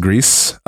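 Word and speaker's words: Gris: It's also on PC and Mac, I believe, called, I believe it's pronounced Grease. It is Spanish for the color gray.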